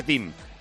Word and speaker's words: Thiem: Bueno, resulta que se dice Thiem.